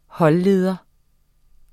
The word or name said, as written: holdleder